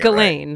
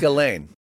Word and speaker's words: Ghislaine: You're probably saying it Ghislaine. – Right. – Ghislaine. Yeah. – It's Ghislaine.